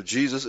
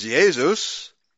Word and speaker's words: Jesus: Of course, the German pronunciation of Jesus is Jesus, and they didn't have the change in the pronunciation of the letter J as we do in English. The letter J in German still is pronounced like a Y.